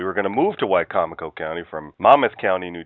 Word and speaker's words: Wicomico: I must say, Dave, you really impressed me with your pronunciation of Wicomico,